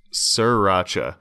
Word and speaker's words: sriracha: And it's gotten worse because I think Jack in the Box has, they have a thing where they get the guy saying, no, it's pronounced sriracha.